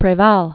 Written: Rene Garcia Preval

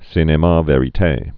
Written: cinema verite

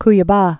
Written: Cuiaba

